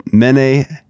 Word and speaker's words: Mene: Could you explain the, quote, writing on the wall and the words, I'm going to mess up this pronunciation, but Mene, Tekel, and Perez, what does it mean? – You know, that was good pronunciation.